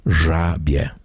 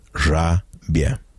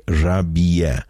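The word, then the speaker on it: rybie: So the correct pronunciation of the noun in the dative case in that first example is rabie. Rabie. It has two syllables. Please do not say rabie with three syllables. Again, the correct pronunciation is rabie. Rabie. Two syllables.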